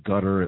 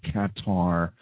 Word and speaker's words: Qatar: They call it Qatar. It looks like it's spelled Qatar.